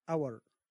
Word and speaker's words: hour: كلمة H أيضا في honor, hour, honest, ghost.